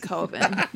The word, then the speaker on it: Coven: Yeah, Coven. – It's pronounced like oven. – Oh, I thought you were saying it's pronounced like COVID.